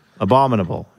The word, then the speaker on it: abominable: Abominable. – It's that quick middle syllable that gets you. – And the key is you got to say it real quick.